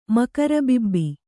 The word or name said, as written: ಮಕರಬಿಬ್ಬಿ